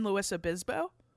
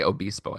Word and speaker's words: Luis Obispo: I've also gone to mass at the San Luis Obispo. – Yeah, you said it fine. – Yeah. – You can say Obispo, I guess.